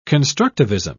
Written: constructivism

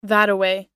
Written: thataway

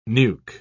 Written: nuke

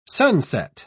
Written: sunset

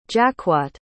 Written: jacquotte

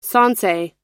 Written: sansei

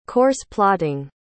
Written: course-plotting

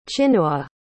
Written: chinua

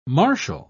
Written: marshal

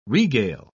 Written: regale

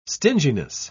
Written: stinginess

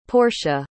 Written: Porsche

